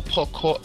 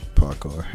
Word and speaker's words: parkour: I mean, it's okay, the whole parkour. How you say it? – Parkour.